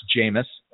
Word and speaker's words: Jameis: Sheamus at 6,400. – That's pronounced Jameis.